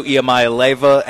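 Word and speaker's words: Iamaleava: You have Nico Iamaleva at Tennessee. – That's how you say it.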